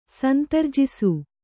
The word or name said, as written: ಸಂತರ್ಜಿಸು